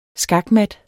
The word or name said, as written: skakmat